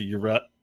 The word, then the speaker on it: Yrel: And so it's a Yarel release. I said it correctly, I think. – I'm proud of you.